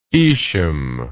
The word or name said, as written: Ishum